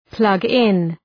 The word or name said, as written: plug in